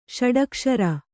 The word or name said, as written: ಷಡಕ್ಷರ